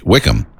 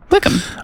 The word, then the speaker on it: Wickham: How do you say it? Wick? Wickham. – Wickham.